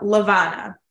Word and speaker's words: LaVonna: Today we have LaVonna mm-hmm. and LaVonna Roth. She's yeah, good, isn't she? – She is. And I always want to call her Madonna because her name rhymes with that.